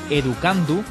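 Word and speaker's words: Educandoo: Se llama Educandu, aunque se escribe Educando-o, con dos O's.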